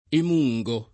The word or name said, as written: emungere